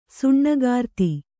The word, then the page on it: ಸುಣ್ಣಗಾರ್ತಿ: Kannada